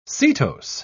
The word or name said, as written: setose